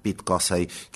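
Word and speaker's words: Pete Cosey: y al gran Pete Cossey, que hizo los solos en el canal izquierdo. ¿Sí? Lo dije bien. Sí, lo dije bien. Pete Cossey, canal izquierdo.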